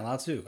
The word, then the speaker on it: Lao Tzu: As a Chinese philosopher and writer, and I'm probably going to butcher the name, but it's Lao Tzu. – Oh, yeah, Lao Tzu.